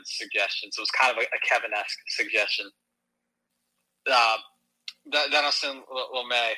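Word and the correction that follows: Dinelson Lamet: I don't know how you say his name, just because I don't like to talk about the guy because he sucks. He just got DFA'd by the Rockies. – Denelson Lamet. – Denelson Lamet. Thank you.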